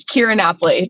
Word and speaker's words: Keara Napoli: It's I get so many Kieras and I get so many Napolis. So that was perfect. It's Keara Napoli. That was that's exactly how you say it.